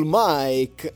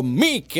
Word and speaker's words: Mic: Siamo in Italia, è scritto M-I-C, io dico Mick.